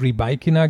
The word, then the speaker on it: Rybakina: Also sie hat der BBC wohl wirklich gesagt, die hat nachgefragt, die BBC fragt mal nach, hat das eben dieses Rybakina gesagt und so hat sie dann zu Barker auch ausgesprochen. Er hat dann extra darauf geachtet.